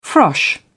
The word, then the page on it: frosh: British English